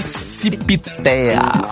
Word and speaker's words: CIPTEA: O P é mudo, hein, gente? – Cipiteia.